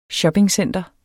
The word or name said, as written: shoppingcenter